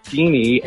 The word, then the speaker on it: Feeney: After I said Mr. Finney, I'm like, oh, it's Feeney.